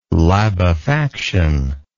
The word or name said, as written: labefaction